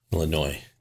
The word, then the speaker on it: Illinois: Illinois. The S is silent.